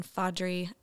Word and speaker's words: Faudree: Yes, so it's Brooke, B R O O K E, and then Faudree, F A U D R E E. Uh, French name no one knows how to pronounce.